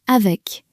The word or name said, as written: avec